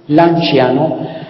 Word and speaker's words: Lanciano: Lanciano, la C se pronuncia como che suave.